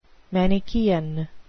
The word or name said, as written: manichean